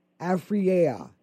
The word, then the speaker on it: Afriyea: Now, that is spelled A-F-R-I-Y-E-A. Afreya. Afreya.